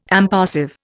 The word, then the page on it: impassive: Indian